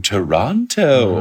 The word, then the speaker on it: Toronto: No, actually, I'm going to Toronto tomorrow. – Ooh, Toronto, or as I've been told to pronounce, Toronto. Yeah. I learned the hard way because I've always said... There's no second T.